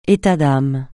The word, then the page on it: état d'âme: français